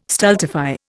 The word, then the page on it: stultify: Indian